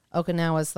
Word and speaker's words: Okinawa: When you break it down on all the places, Okinawa, where else? Did I say that right? – Okinawa.